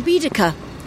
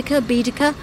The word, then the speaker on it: Baedeker: But the other element which people don't realise is that Eliot was an American visiting London, and he used the Baedeker, if I've said that correctly, Baedeker.